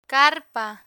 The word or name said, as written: carpa